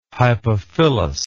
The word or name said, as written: hypophyllous